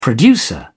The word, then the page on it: producer: British English